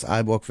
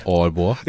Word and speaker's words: Aalborg: Richtig? Oder ist falsch ausgesprochen? – Auf Deutsch ist das richtig. – Und auf Dänisch? – Aalborg.